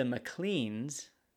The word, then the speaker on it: Maclean: Also, the Macleans in Scotland are, it's generally pronounced always Maclean, even when it's the L-E-A-N, which is interesting.